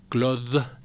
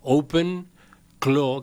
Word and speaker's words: OpenClaw: No es Claude, sino Clo. Open Clo, que se escribe C-L-A-W, ¿no? – Es correcto.